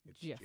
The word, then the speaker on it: GIF: And then even most recently at the Webby Awards, this past Webby Awards, he reiterated, by the way, it's pronounced GIF.